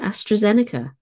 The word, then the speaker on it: AstraZeneca: AstraZeneca.